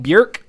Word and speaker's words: Björk: If you like Björk, Rhymes with Jerk.